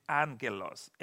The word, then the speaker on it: angelos: You see, I pronounce it angelos, and it has two G's in Greek. When you have two G's in Greek, the first one is pronounced as an N, angelos, and that's where you have angels, okay?